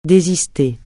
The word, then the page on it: désister: francais